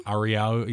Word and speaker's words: Ariau: So this hotel, I don't know how to say it. Ariau? – Ariau, yeah, you did. You put a little Japanese sorry.